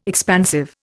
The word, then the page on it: expansive: Indian